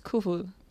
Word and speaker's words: Coefoed: En español sí, en danés no. – Ah, ¿cómo es en danés? – En danés Kufud.